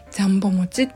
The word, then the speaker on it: 両棒餅: いこれ実は鹿児島の名物まあ、ご当地グルメっていうんですかねなんですけれどジャンボ餅というのがあって漢字であの両方の量に棒あの、犬も歩けば棒に当たるの棒で、ジャンボ餅って読むんですね。